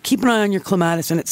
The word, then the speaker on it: clematis: But the most common clematis, or clematis, as it's properly pronounced, is it's called Jackmanni, which is likely the one you have.